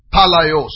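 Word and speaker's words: palaios (παλαιός): Is the word palaios. I'll spell it for you. P-A-L-A-I-O-S. P-A-L-A-I-O-S. It's taken from another word. P-A-L-A-I. P-A-L-A-I. P-A-L-A-I, palai.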